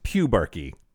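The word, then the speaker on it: pubarche: It is pronounced pubarchy.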